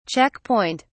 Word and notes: check point: British English

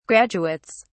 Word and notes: graduates: British English

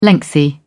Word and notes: lengthy: British English

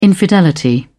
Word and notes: infidelity: British English

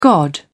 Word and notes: god: British English

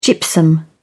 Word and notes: gypsum: British English

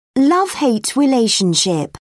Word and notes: love hate relationship: British English

